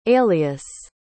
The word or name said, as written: aelius